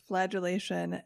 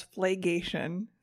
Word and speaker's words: flagellation: Let me just say that last week I pronounced flagellation as flagation, so points to me.